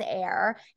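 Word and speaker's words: Aire: And I loved that his name, so she said his name is pronounced as Air, and it's A-I-R-E, and it's of Hebrew origin, and it means like strength of a lion, which right.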